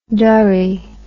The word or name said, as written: durry